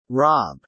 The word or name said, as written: rob